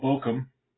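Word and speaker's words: Baucham: So those of you who have been following our midweek series here of late, you know that I've been using Vadi Bachman's book, Vadi Bochum, I guess it'd be the correct pronunciation.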